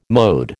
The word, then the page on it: mode: American English